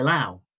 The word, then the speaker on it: Laos: So, first up, we'll get straight into it, is a beer from Laos called Beer Lao. Now, I've deliberately made a mistake there. It's the fact that the country that's spelt Laos is actually pronounced Lao. So the S is silent.